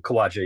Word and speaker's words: Khwaja: It actually is Aamar and Khwaja.